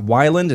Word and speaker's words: Weiland: Number four, Scott Weiland.